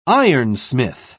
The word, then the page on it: ironsmith: British English